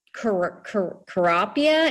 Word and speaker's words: Kurapia: It's a turf alternative, and it's called Carapia, and it's spelled Kurapia.